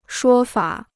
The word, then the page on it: 说法: shuō fa